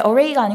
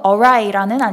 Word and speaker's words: awry: 첫 번째 기사에서 저희 그 going off track에 대해서 얘기할 때 비슷한 유사 단어로 derail과 going array라는 표현을 했는데 array가 아니고 awry라는 아니타의 예, 보충 설명입니다. 굉장히 흔한 pronunciation 실수이고요. 저희 청취자분들은 array 아니고 awry로 잘 기억해 주셨으면 감사하겠습니다.